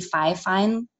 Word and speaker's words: Fifine: It's probably Fifine, like hi-fi. So it's F-I-F-I-N-E.